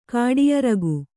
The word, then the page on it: ಕಾಡಿಯರಗು: Kannada